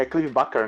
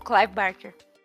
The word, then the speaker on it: Clive Barker: Um conto, É Clive Barker, né? Eu tô falando, sabe? – O Clive Barker.